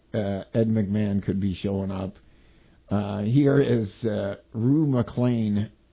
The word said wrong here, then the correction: McClanahan: McClanahan. – McClanahan? Is that how it's pronounced? – Yes. – Rue McClanahan.